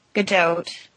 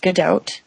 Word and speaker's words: Gadot: But I think Gal Gadot, that's how it's pronounced. I looked it up. It's Gadot. I think her attitude towards Wonder Woman,